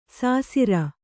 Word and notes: ಸಸಾರ: sasāra

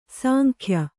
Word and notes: ಸಾಂಗ್ಯ: sāngya

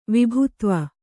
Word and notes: ವಿಭುತ್ವ: vibhutva

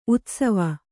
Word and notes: ಉತ್ಸವ: utsava

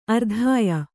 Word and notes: ಆರಾಧ್ಯ: ārādhya

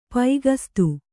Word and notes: ಪೈಗಸ್ತಿ: paigasti

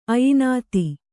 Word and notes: ಅಯಿನಾತಿ: Kannada

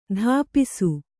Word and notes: ತಪಿಸು: tapisu

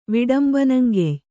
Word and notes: ವಿಡಂಬನಂಗೆಯ್: Kannada

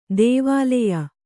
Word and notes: ಧಾವಲ್ಯ: Kannada

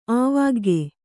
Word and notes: ಆವಾಗ್ಗೆ: Kannada

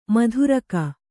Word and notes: ಮಾತೃಕ: mātřka; Kannada

